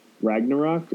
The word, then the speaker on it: Ragnarok: Thor Ragnarok.